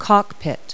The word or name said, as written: cockpit